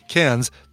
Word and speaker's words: Cairns: I know you're saying it's pronounced Cairns. Take it as an Aussie, Cairns. That's how you pronounce it.